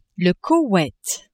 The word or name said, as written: Koweit